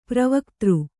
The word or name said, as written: ಪೂರ್ವಗತ